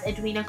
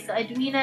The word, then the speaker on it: Edwina: So IG is at Edwina Kulego, Facebook is Edwina Kulego. – Just spell that for people, just so they know. So Edwina is E-D-W-I-N-A, Kulego is K-U-L-E-G-O.